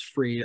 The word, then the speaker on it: Fried: He's I mean, I'm sorry, I just can't get past the fact that you called him Fried, even though his name's Freed. I know it's spelled Fried, but it is.